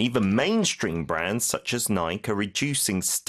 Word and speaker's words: Nike: 항상 나올 때마다 왜 미국 브랜드 나이키를 영국 사람들은 나이크라고 읽는지. – 마음대로 나이크. – 네. 자, even mainstream brands such as Nike are reducing staff members.